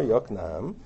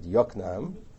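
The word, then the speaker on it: Yokneam: which drives me nuts because they pronounce it Yoknaam. Just don't understand how that came about. It's not Yoknaam, it's Yoknaam, as all cities... even in Israel they say Yoknaam. Right? It's Yoknaam. The accent is, the emphasis is on the wrong syllable.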